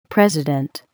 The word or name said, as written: president